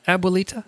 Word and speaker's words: abuelita: Abuelita? – No, you got it. I love listening to non-Spanish speakers say abuelita.